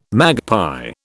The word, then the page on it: magpie: British English